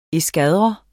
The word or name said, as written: eskadre